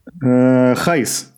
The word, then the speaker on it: Gijs: So, Gijs it is then.